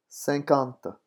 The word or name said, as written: cinquante